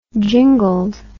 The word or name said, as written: jingled